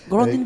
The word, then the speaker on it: Grandin: Grandine, je ne sais pas comment on prononce. – Grandine pour les Américains, Grandin pour nous.